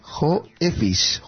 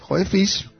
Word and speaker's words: Joeffic: Este es un nombre un poco raro. Vamos a intentarlo pronunciar. J-O-E-F-F-I-C. Joefis. Joefis. Se trata de, bueno, de una nueva suite.